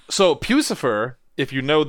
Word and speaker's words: Puscifer: P-U-S-C-I-F-E-R. – Almost like, I think it's Lucifer, but it's Pussifer. – He's running. – It's Pussifer.